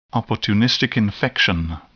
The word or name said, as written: opportunistic infection